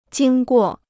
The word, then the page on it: 经过: jīng guò